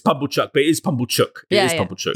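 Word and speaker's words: Pumblechook: Have I been calling him Bumblechuck? – Yeah, at one point you did, but I enjoyed it, so I didn't. – But correct. – It is Pumblechuck is obviously the canonical pronunciation.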